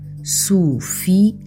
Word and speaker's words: Sofia: No caso do nome próprio Sofia, a pronúncia efetua-se com um hiato entre I e A, Sofia, o que nos indica que esta palavra é um trissílabo, ou seja, tem três sílabas. Quanto à acentuação, verifica-se que a sílaba tónica, aquela que é dita com mais força, se encontra na sílaba fi, pelo que a palavra é paroxítona ou grave.